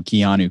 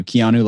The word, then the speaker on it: Keanu: Keanu. Keanu like Keanu Reeves, but my last name is Trujillo.